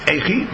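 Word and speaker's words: Eichi (אחי): So there's a Pirush Madani Yomtif on the Rosh that says the name should be pronounced Eichi because it's the same name as one of the younger children of Binyamin.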